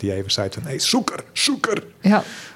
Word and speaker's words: sûker: Dan wordt het altijd suikerpoes. Oh, ja. Suikerpoes, ja, dat klinkt toch nergens. Nee. Het is zoeker, zoeker, ja, zoeker.